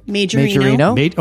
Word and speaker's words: Majorino: Majorino.